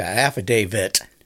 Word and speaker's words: affidavit: Yeah, affidavit. – Uh-huh. – You always put the T sound at the end of it. It's not like King David.